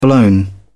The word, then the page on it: blown: British English